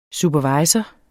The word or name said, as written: supervisor